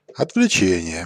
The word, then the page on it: отвлечение: /ətvlʲɪˈt͡ɕenʲɪjə/; otvletšenije